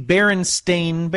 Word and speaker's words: Berenstain: Just like Baron, and then Stain. – Stain. It's the Berenstain Bears, Matt.